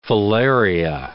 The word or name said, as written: filaria